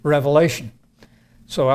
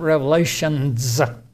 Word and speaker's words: Revelation: We usually shorten that just down to Revelation, so I'll be doing that. And by the way, it's not Revelations, it's Revelation, the Revelation.